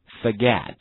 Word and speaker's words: forget: 好，忘记和原谅这两个单词的话，一定注意同学们 OR 的发音是发不是发 O，所以读成 forget forget， forgive forgive。